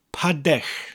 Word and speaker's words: padell: Now, bowl in Welsh is padech, spelt P-A-D-E-L-L. Padech. I'll slow the word down for you. Padech. Now, the first part is easy enough. It's pronounced exactly like the English word, pad, as in the slang term for a home or dwelling.